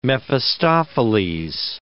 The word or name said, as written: Mephistopheles